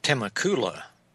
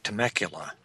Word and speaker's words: Temecula: Well, you know, that's supposed to be pronounced Temecula, not Temecula, right? – Okay, well, then, pardon me.